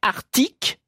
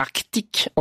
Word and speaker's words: Arctique: j'ai toujours prononcé Arctique et Antarctique sans le C.